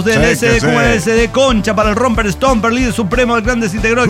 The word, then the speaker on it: Romper Stomper: Romper Stomper, es más, lo pronunciamos como el culo toda la vida porque era Romper Stomper y nosotros le decíamos Romper Stromber, no sé qué pedo.